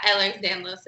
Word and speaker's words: Ehlers-Danlos: Ehlers-Danlos syndrome. – Close. Yeah, Ehlers-Danlos syndrome.